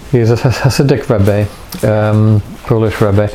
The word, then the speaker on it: Rebbe: K-O-T-Z-K-B-E-R. Kotzka, and then it's Rebbe, R-E-B-B-E. It's the it's rabbi really, but it's the Hasidic traditions of Eastern Europe slightly alter the pronunciation of rabbi to Rebbe. It's just a sort of dialect thing really. Z-K-B-E-R.